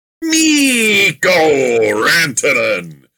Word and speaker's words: Mikko Rantanen: Mikko Rantanen.